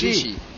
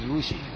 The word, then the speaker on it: Rishi: Rishi. Oh, Rishi, yes. Rishi. – You call it Rishi, we call it Rushi. That is the difference in pronunciation.